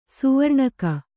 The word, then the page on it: ಸಾವರ್ಣಿಕ: sāvarṇika